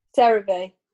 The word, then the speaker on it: CeraVe: CeraVe. – CeraVe.